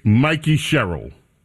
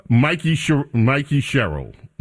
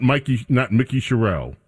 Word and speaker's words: Mikey Sherrill: It's Mikey Sherrill. Mikey Sherrill, not Mickey Sherrill.